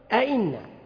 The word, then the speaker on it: أئن: أئن، أئن، همزة ثم يعقبها همزة مكسورة، يقول أئن. آ الأولى واضحة، آ، ثم الثانية بين الهمز وبين الياء، أئن. فيها تسهيل، تخفيفا في النطق، تيسيرا في النطق.